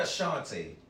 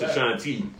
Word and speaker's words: Ashanti: I want to fuck Ashanti. So I'm trying to take that out there. – Yeah, but that's probably not going to happen. Especially if you're saying her name wrong. Yeah, it's Ashanti.